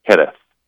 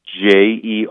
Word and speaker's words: Jerez: The region what you'll see on bottles, just so people don't get confused, they pronounce it Jerez. So it's Jerez, or you'll see XE with an accent, Aiguë with an accent, grave S, but it's sherry, right?